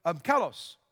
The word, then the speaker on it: kalos: Honorable is kalos, got to get the pronunciation right. But kalos.